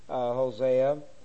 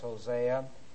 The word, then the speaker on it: Hosea: And that's Hosea. And this is the H, and then this is a long O. And actually, if we want it to be more correct, what we have is a hard breathing SH sound, but we'll give it the S sound, and then we have the vowel underneath, and then the next vowel.